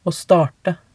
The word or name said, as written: å starte